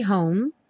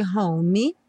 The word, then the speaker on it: home: I'll be home for Christmas. E o home, sem pronunciar o E, nada de home.